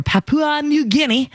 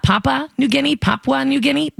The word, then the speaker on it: Papua New Guinea: It was found on the ocean floor near Papua New Guinea. I definitely butchered that. Papua New Guinea.